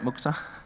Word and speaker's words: Muktza: Muktza. Is that how you say it? Muktza? Yeah. Muktza.